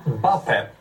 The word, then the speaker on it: Mbappé: Mbappé. Och det är nyttigt att man ska in bakom ytan, bakom deras huvudslinning. – Ja, Mbappé, rätt uttal.